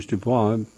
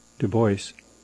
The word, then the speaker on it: Du Bois: Du, well, there's two pronunciations, 19th century Du Bois, but he pronounced it Du Bois, and I'll talk about that.